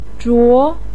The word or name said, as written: zhuo2